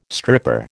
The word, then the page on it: stripper: American English